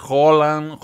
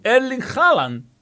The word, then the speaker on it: Erling Haaland: Pero sabéis que weón, yo he escuchado cada chantete que es Holland, Holland, weón. No, Erling Haaland, listo.